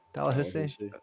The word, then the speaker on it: Dalhousie: I said that right, Dalhousie. – Dalhousie.